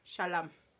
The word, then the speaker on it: Chalamet: No, Timothy Chalamet. Chalamet. – Chalamet. – Chalamet. Porque el no se pronuncia porque es francés.